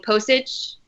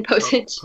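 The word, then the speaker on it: Pocic: Are we assuming Postage? Am I saying his name right? Ethan Postage. – I think it's a hard C at the end.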